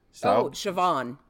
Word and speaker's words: Siobhan: It's Siobhan. – Siobhan.